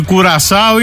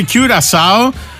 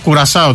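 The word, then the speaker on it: Curaçao: Μανιάνα και θα επιμείνω και σε σένα, δεν υπάρχει κουρασάο, είναι κιουρασάο. Λέγεται το ρημάδι το νησί. Ναι, η αλήθεια είναι ότι στα ελληνικά κουρασάω το λέμε. Έτσι έχει επικρατήσει. Κιουρασάω είναι η προφορά η αγγλική.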